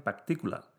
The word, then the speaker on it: particular: porque los ingleses pronuncian particular, que la hacen estrújula.